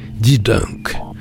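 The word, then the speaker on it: De Dunk: Mais comme son auteur euh, est une jolie jeune femme américaine, son titre s'écrit D-E espace D-U-N-K. Dis donc. Voilà pour la phonétique.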